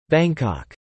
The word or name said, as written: Bangkok